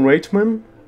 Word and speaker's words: Reitman: É, é, o filme vai ser dirigido por, pelo diretor Jason Reitman. Eu não sei como se pronuncia o, o sobrenome. R-E-I-T-M-A-N. Reitman.